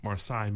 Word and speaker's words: Marsai: Marsai. Marsai Martin.